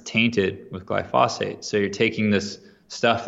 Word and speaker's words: glyphosate: So glyphosate, I pronounce it glyphosate, and it's basically Roundup.